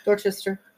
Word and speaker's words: Dorchester: Well, numerous ghosts are reported to roam Athelhampton House, which is a large estate in Dorset near Dorchester, England. I hope I said that right. – Dorchester. Yep.